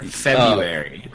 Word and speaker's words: February: You skip over the R. February.